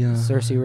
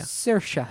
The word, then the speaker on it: Saoirse: Saoirse.